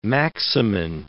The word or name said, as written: maximin